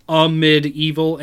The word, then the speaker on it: Amid Evil: Amid Evil, A-M-I-D, Evil.